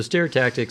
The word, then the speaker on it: stereotactic: So stereotactic, stereotaxy is really... – Say it again? Did I say it wrong? – No, stereotactic.